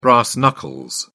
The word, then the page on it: brass knuckles: British English